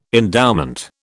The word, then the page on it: endowment: British English